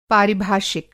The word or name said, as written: पारिभाषिक